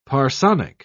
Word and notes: parsonic: British English

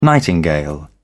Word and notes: nightingale: British English

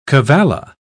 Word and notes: cavalla: British English

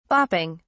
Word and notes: bopping: British English

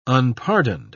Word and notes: unpardoned: British English

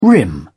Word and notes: rim: British English

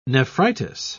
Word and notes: nephritis: British English

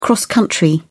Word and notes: cross-country: British English

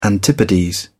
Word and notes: antipodes: British English

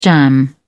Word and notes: jam: British English

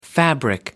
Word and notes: fabric: American English

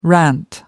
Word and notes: rant: American English

